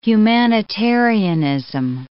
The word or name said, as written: humanitarianism